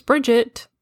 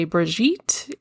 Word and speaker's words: Brigitte: And I explained to him, you know, my name is spelt B-R-I-G-I-T-T-E. And the American pronunciation is Bridget. If you're French, you can say Brigitte.